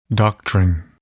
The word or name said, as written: doctrine